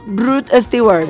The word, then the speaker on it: Rod Stewart: No, pero se escribe Stewart. – No, pero es Rod Stewart. Rod Stewart. – ¿No es Stuart Little? – Rod Stewart. – Pero si se escribe Stewart, Stewart, Stewart. Bueno, está bien, Rod Stewart.